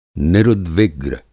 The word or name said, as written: निरुद्विग्र